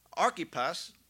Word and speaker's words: Archippus: One of which was directed to a man named Archippus. That's how you pronounce it.